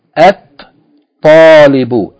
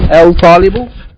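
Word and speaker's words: At-talibu (الطالب): Therefore, if we add alif lam, then we're not going to pronounce the lam, rather we're going to merge the lam into the sun letter. We're going to merge the lam into the sun letter, and we're going to pronounce the sun letter twice. So, talibun with alif lam becomes At-talibu, at-talibu, not al-talibu, at-talibu.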